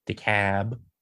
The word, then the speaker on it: DeKalb: DeKalb County, or as they pronounce it down here, DeKalb, and then other places.